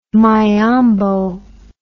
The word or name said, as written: miombo